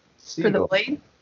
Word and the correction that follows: Sigil: Sigil. – Sigil. Did I say that wrong? Sorry about that. – Sigil, I think it's called the Sigil, yeah.